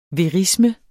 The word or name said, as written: verisme